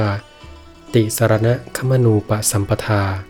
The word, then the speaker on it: ติสรณคมนูปสัมปทา: อ่านว่าติสารณะขมนูปะสัมปทา